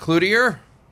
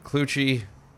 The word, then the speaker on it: Cloutier: Mike Cloutier, Mike Clucci, same guy. Same guy. Same spelling, but different pronunciations.